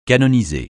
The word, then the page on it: canoniser: francais